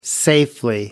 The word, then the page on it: safely: American English